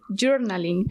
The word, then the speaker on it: journaling: A ver esa pronunciación, repite. – Journaling.